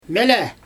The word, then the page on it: mjAlA: Xyzyl